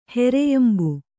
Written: ಹೆರೆಯಂಬು

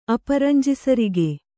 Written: ಅಪರಂಜಿಸರಿಗೆ

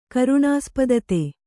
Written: ಕರುಣಾಸ್ಪದತೆ

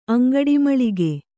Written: ಅಂಗಡಿಮೂಲಿಕೆ

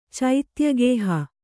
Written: ಚೈತ್ಯಗೇಹ

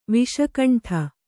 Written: ವಿಷಕಂಠ

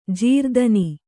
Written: ಜಾರತನ

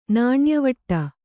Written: ನಾಣ್ಯವಟ್ಟ

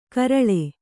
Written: ಕೆರಳ್

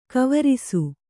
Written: ಗಾವರಸ

